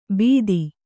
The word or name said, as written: ಬಿತ್ತಿ